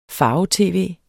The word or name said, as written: farve-tv